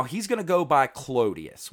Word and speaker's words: Clodius: Well, that's what we're going to call him from now on, because Claudius was the like fancy Roman pronunciation of it. He wanted to be a man of the people, so he changed it to the way the plebs pronounced it and said Clodius.